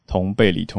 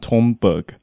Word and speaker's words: Thunberg: 就是用用瑞典文念是同贝里，所以后来有一些媒体就是开始所谓证明，就是他应该要念同贝里（Thunberg）。可是很多人看到那个字会先念成 Thunberg，然后就会写翻成同贝格或者 Thunberg，因为它是 th。